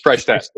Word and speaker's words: Freishtat: Freishtat.